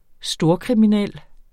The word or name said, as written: storkriminel